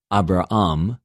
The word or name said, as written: Ἀβρααμ